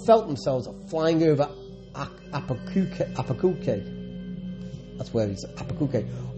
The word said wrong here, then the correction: Albuquerque: or visiting their relatives' home in Toledo. Albuquerque, sorry, it's Albuquerque pronounced, isn't it? A L B U Q U E R Q U E, Apokirky.